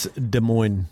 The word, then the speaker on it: Des Moines: As on Thursday last week, Iowa officials in the Des Moines County, no idea how to pronounce it, but I'm going with Des Moines. – It's Des Moines.